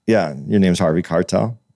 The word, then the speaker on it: Keitel: Harvey Keitel. – Keitel. You about to fuck me up. You said Harvey Keitel? – Keitel, yeah.